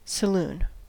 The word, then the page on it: saloon: American English